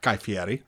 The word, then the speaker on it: Guy Fieri: Guy Fieri. – All right, cool. Thank you. – Now you say it. – Hey, Guy Fieri.